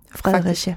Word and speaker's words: Fredericia: Fredericia. – Faktisk. I stor vrede også. – Der er ikke te i Fredericia.